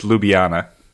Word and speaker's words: Ljubljana: In my best American accent, it's Ljubljana. – Okay. – I don't think you pronounce the J. – The J is silent.